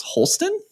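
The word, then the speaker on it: Holstin: My next one, I had to ask for help on the title of this game earlier before we started recording, but I believe it's pronounced Holston. – Yes, it is.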